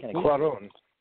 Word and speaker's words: Cuarón: Cuaron. It's an O. Cuaron. It's not an A. You say Cuaron like it's C-U-A-R-A-N. It's C-U-A-R-O-N, and there's even an accent mark over the O. Cuaron.